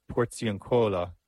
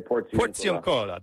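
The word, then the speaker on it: Porziuncola: But let's start with tomorrow, because tomorrow I'm not going to say this properly, but at the Porzioncola, lying on the bare ground. Was that close? Okay. Help me out here. – We would say Porzioncola. – There you go. Porzioncola.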